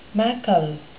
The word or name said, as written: mackle